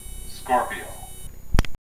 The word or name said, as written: Scorpio